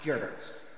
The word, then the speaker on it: Goebbels: afforded deific power to an individual whose right-hand man's name was spelled Goebbels, but pronounced Gerbers.